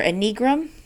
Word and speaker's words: Enneagram: I'm probably saying it wrong, but Enneagram, E-N-N-E-A-G-R-A-M, Enneagram, and I think it's Enneagram or Enneagram.